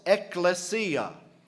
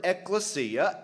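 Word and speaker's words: ekklesia (ἐκκλησία): The Greek word is pronounced ekklesia. It's the word ekklesia.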